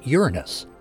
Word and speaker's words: Uranus: Right now is the best time to see the seventh planet from the Sun, the planet Uranus. And yes, I'm pronouncing that correctly.